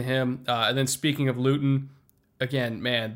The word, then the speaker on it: Luton: Like, I know they had, you know, only 3 points on the day because they're the Jags, and Jake Luton, however you pronounce it. – It's Luton. – Yep. Luton.